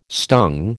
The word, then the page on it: stung: American English